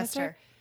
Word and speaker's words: Rochester: Am I saying that right? – Rochester. Rochester. Yeah. – But if you say it like slow, it doesn't sound right at all.